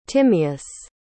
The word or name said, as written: Timeus